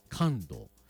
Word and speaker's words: 寒露: 二十四節気の一つ、昨日 10 月 8 日が寒露だったんですね。寒いに梅雨と書いてね、寒露。